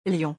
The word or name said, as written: lion